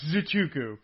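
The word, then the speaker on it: Nzechukwu: You're not, but that I've given you a pass. I'm giving you a pass on this one because it ain't easy. It's Enzichukwu.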